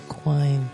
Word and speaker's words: Quine: Quine? – Yep.